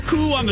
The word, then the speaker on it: coupon: Because that's how you pronounce it. It's if you're putting O-U, it's like a coup. I'm going to commit a coup on this radio station and take it over from B. Arthur. It's not, I'm going to do a Q. A Q. It's a coup. Cuckoo clock. It's not cuckoo clock. It's cuckoo clock. So it's coupon.